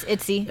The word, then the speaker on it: ITZY: And going on up to number 3, it is Voltage by ITZY. I believe that's how you pronounce them. – Yep, it's ITZY.